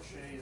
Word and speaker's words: Shea: DeCamp actually appears in two rows, but the second row, DeCamp and Pratt, I call it Shea. Harold Shea series. Is that how you pronounce it? – I'm from New York. We have Shea Stadium. – There you go, Shea Stadium.